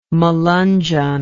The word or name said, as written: Melungeon